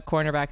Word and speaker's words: cornerback: I'm a cornerback. And they're like, quarterback. And they're like, cornerback. – Yeah, you have to make sure you really pronounce the N in that. Cornerback.